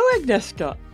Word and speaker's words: Agnieszka: Welcome to the show, Agnieszka. – Yeah, hello. I'm so glad you've pronounced Agnieszka like my Polish background. It's really hard for international pronunciation, but you just nailed it.